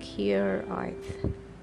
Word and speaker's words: hiraeth: The book is anchored in the Welsh word hiraeth, pronounce it like hiraeth, and please, for heaven's sake, roll the R.